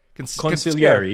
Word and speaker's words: consigliere: No, the G is silent. You don't say the sig. It's consigliere. – Consigliere. – Yeah, just pretend the G's not there.